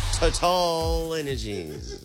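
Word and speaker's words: TotalEnergies: Total Energies. I know it still sounds funny, but that's the correct pronunciation. – I like the way you say it. Say it again. – Total is French.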